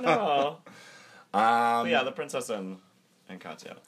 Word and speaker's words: Katya: Katya. – Katya. – She'll catch ya.